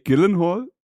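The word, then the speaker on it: Gyllenhaal: Jake Gillenhall? Ja, perfekt. Sagt man das so? – Ja, Oder willst du ihn Gillenhall? Genauso ausgesprochen, ist ja gar nicht so schwer. Ja, aber er hat seinen Namen ja selbst mal ausgesprochen, das klingt ja total anders. – Er hat gesagt, die einzigen Leute, die seinen Namen richtig aussprechen, sind die in Schweden und bei Ikea.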